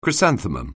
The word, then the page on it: chrysanthemum: anglais